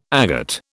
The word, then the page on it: agate: British English